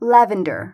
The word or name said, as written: lavender